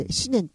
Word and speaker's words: 思念: それを思念って言葉、要するに思うっていう言葉に念が入って思念って読むんですけど、思念が入ってるんですって。